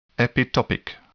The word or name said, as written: epitopic